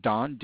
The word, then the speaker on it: Don: My name is Don Rhodes, R-H-O-D-E-S is the last name. First name, Don, D-O-N.